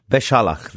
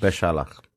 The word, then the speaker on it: Beshalach: How do you pronounce it? – Beshalach, that's Beshalach. – Correct. Beshalach.